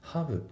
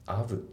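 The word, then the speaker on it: have: で、あとその発音なんだけど、このハブのところがね、聞き取りづらかったなっていう人が多いと思うんだけど、この H が抜けてるんですよね。ハブって発音されてないですよね。アブってなってますね。 The position of all beings filled、ね、ハブじゃなくてアブになってるから、この H っていうのはね、よく抜けることが多いんですよね。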